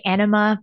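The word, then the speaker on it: Ænema: Anema? Am I pronouncing that right? – No, nobody pronounced it right. – What is it? – Some people say enema, but it's spelled like anema.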